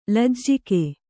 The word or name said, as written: ಲಜ್ಜೆಗಾ